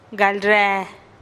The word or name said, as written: gal ree